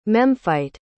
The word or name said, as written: Memphite